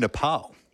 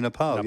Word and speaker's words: Nepal: Apparently it's actually Nepal. – It is Nepal. – Yes. Yep. We say Nepal in Australia, but it's actually Nepal. Nepal.